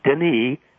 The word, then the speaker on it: Denis: But if you just Google his name, Denis Denis, silent S in French, Rancourt, R-A-N-C-O-U-R-T.